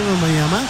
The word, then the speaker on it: Wembanyama: En el segundo medio del partido, ahí está la gran diferencia hoy para Messier Wembanyama, como dice que se debe pronunciar en francés.